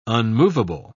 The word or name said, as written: unmovable